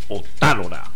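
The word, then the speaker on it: Otálora: Justo Briceño Otalora, acento en la A.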